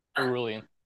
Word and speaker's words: Erulian: Erulian.